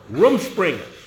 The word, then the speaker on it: Rumspringa: Rumspringa.